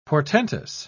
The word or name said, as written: portentous